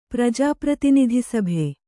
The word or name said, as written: ಪ್ರಜಾಪ್ರತಿನಿಧಿಸಭೆ